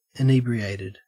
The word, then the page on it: inebriated: Australian English